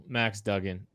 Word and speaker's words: Duggan: Max Duggan, I'm sorry, I, I, you said it right. I said it wrong earlier.